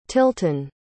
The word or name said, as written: tilton